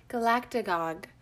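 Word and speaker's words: galactagogue: Okay, the trick is to slow it down. – Galactagog. – Galactagog. Galactagog. Give that short A its rightful place in the word.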